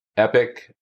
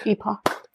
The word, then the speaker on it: Epoch: But you can go to Trump on Epic or Epoch. – Epoch.